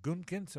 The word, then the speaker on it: Gutekunst: Have the Packers suddenly arrived, and did Brian Gutekunst, am I saying it right? – Yeah.